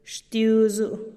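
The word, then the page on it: xtiuzu-u: Teotitlán del Valle Zapotec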